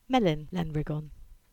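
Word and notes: Lariggan Mill: Cornish

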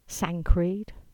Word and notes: Sancreed: English

